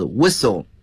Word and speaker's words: whistle: 大家注意这个词啊， WR 开头，那么往往有时呢这个 W 是不发音的，比如说这个吹哨子 Whistle 啊，像这里 Wrestle W 都是不发音的，在英语中被称作 unvoiced 啊，不发音，摔跤。